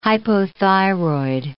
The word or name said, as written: hypothyroid